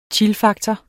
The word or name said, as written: chillfaktor